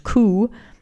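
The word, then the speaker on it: ku (九): The number nine is almost as bad because it's pronounced ku, the same as the word for suffering.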